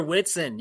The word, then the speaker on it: Whitson: Whitson.